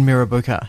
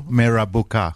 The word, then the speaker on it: Mirrabooka: What was it like to grow up in Mirrabooka? How am I saying? Am I saying that right? – No. – No. – You're not saying it right. – At all. – Mirrabooka. – Mirrabooka. – Bingo.